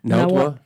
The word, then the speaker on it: Nahuatl: Nahuatl. Nahuatl. – Nahuatl. – Nahuatl. If you figure it out, people, it's Nahuatl. – Yeah, Nahuatl.